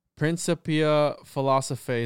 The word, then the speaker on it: Principia Philosophiae: In his work, and bear with me, Principia Philosophiae, is that how you say it? – Yeah, you nailed it.